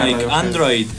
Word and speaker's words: Paranoid Android: Paranoid Android, que es difícil de decir y lo dije bien.